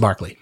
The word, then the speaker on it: Berkeley: The word Berkeley, like Berkeley, California, there's a, I think it's a famous scientist, might have been a philosopher, but it's pronounced Barclay.